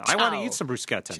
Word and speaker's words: bruschetta: It's pronounced bruschetta." She gave us the right pronunciation. – Oh, bruschetta. – Bruschetta. – Bruschetta. But you've got to do it bruschetta. – The CH is pronounced as a K.